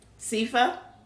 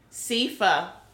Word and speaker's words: Sifa: Sifa. – Say it again. – Sifa. – Is it S-E or S-I? – S-I. – F-F? S-O-S-I-F-A.